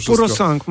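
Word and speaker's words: Purosangue: Purosang? Dobrze to wymawiam, bo już... – Tak, Purosang.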